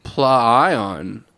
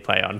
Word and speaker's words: PLAION: Kosh Media has rebranded to Play On, but it's spelt PLAION. So you could say Plion. Plion. – Nah, my first... – It's cool, but it's Play On.